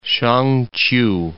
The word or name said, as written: Shang-ch'iu